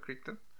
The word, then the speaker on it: Crichton: Jurassic Park es una película basada en el libro del mismo nombre de Michael, eh, Michael Crichton. ¿Sí está bien pronunciado Crichton? – Sí.